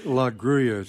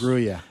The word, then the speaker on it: La Gruella: Yeah, if I may, I kind of have to correct you on the pronunciation. It's a Spanish word. It's La Gruella. It's kind of like tortilla, two L's. – Gruella, okay.